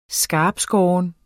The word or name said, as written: skarptskåren